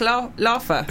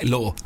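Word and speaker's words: Laugher: Jack Laffer. – Right. His name's Jack Law.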